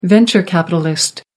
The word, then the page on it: venture capitalist: American English